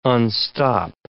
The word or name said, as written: unstop